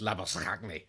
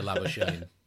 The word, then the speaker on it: Labuschagne: And it's a South African name that should be pronounced Labuschagne. But I mean, even for Australia, this sounded too disgusting, and uh, so he's changed it to to Labashane.